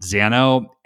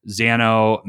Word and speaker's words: Xano: There's a new kid on the block that is a backend as a service, and I believe you pronounce it Xano, X-A-N-O, and this is the first really no code, what I would call backend service.